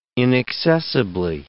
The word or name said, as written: inaccessibly